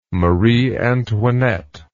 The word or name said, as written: Marie Antoinette